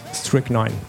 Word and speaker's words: Strychnine: Strychnine. – Strychnine.